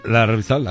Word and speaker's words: Lardizábal: ¿Lardizábal? ¿Lo dije bien? – Lardizábal, ajá.